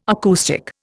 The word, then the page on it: acoustic: Indian